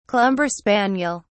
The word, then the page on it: clumber spaniel: British English